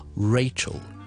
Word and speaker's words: Rachel: 咁呢个英文名咧就系 Rachel， Rachel。